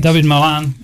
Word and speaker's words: David Malan: David Malan of Middlesex? – David Malan, yeah.